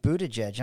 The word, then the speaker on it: Buttigieg: We learned how to say his name, Buttigieg. I mean, my gosh. I mean, Buttigieg.